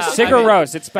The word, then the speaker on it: Sigur Rós: Sigur Rós. I mean, it's spelled S-I-G-U-R-R-O-S.